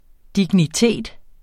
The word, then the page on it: dignitet: [ digniˈteˀd ]; [ dini- ]